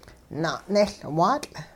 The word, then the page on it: na'nehLwaatL': hupa